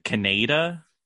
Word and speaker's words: Kaneda: Yeah, and I'm pretty sure they say the name in that version, Kaneda, is how they say it. – Like, oh yeah, yeah. Everybody calls Kaneda Kaneda just because it looks like it's spelled like Canada, but it's with a K, so mm-hmm.